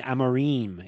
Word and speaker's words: Amorim: And I said, I was saying her name wrong. It was Jacqueline Amarim. It kind of rhymes.